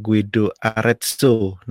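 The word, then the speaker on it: Guido d'Arezzo: Guido Arezzo, Guido Arezzo. Nah, itu dia bacanya.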